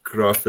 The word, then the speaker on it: Graf: do I say Graf or Graf or? – Yep. Graf is fine.